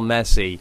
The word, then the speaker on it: Messi: in terms in terms of the soccer world, if he said all this about Lionel Messi. Is that that's how I pronounce it, right? Yeah. And he had literally was showing you photos with him and Lionel Messi and maybe even went so far as to got Lionel Messi on the phone, on speakerphone, for you to say hi to.